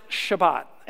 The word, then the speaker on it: Shabbat: A Sabbath is literally a Hebrew word pronounced Shabbat.